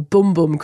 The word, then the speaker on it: Bum Bum: Bum Bum Cream, or Boom Boom Cream, I'm told. It's spelt B-U-M.